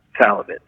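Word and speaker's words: Szalavitz: Salavitz, you don't pronounce the Z, and it's Hungarian name, and SC is one letter.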